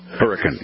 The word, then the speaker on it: Hurricane: If you pronounce it Hurricane Utah when you go there, you will be corrected.